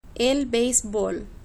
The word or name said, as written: el beisbol